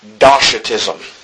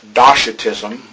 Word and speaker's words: Docetism: Well, with regards to our first heresy, and hopefully I'll pronounce this right, because I have a hard time butchering this word sometimes, I believe it's pronounced Docetism.